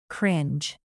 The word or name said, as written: cringe